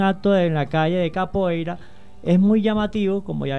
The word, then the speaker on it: Capoeira: Tenemos invitado al día de hoy al joven Ítalo Angulo, el cual tiene un grupo llamado Unión Capoeira Caracas, o Unión Capoeira Caracas, para pronunciarlo un poquito mejor en portugués,